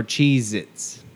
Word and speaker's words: Cheez-It: Cheez-It or Cheez-Its? – Oh, dude. It's Cheez-It. – Yeah, there's no Z at the end.